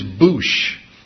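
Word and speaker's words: boosh (בוש): The Hebrew word translated ashamed in chapter 2, verse 25, is boosh. I think it's a funny word, boosh. I'm ashamed, boosh. B-O-O-S-H is the way it's spelled in the English words, translated, it's pronounced boosh in Hebrew.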